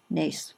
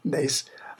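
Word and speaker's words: Naas: Nace. – Nace,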